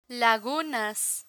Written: lagunas

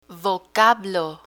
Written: vocablo

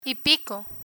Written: y pico

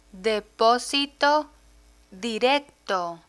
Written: deposito directo

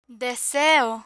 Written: deseo

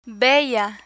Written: bella

